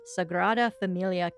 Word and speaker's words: Sagrada Familia: So one of the most amazing tourist attraction we got to see was the famous architect Gaudi's Sagrada Familia Cathedral.